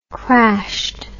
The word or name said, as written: crashed